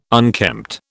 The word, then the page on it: unkempt: British English